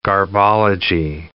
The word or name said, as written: garbology